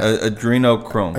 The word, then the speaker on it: adrenochrome: How do you pronounce that? – Adrenochrome.